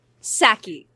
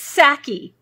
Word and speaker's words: Psaki: Well, and it's pronounced Saki. The P is silent. Because, of course. – That's even worse. – I know. – That's even worse. It's Saki. – Yeah. It's Saki. Not like the Japanese alcohol, because that's sake.